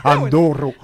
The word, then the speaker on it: enduro: C'est sûrement pas comme je le prononce, là. Enduro. – C'est enduro tout court.